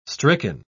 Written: stricken

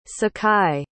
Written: sakai

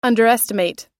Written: underestimate

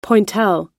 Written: pointelle

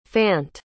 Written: fant

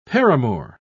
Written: paramour

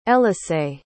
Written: elisae

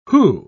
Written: who

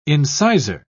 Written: incisor

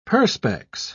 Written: perspex

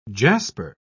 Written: jasper

